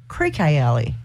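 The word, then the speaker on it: Creeque Alley: How many times people have actually mispronounced? – Creaky. – Creaky. – Creaky. – Creaky. Creaky Alley. It's actually pronounced Creaky Alley.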